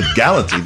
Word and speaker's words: Galati: Is it Galati or Galati? I said, Galati. There's no N in Galati.